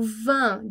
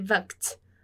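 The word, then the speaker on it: vingt: Porque o vin de 20, aquele GT no final da palavra, a gente não pronuncia.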